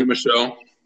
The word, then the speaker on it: Sony Michel: Sony Michel.